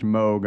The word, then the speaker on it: Moog: I also like the way you pronounced Moog.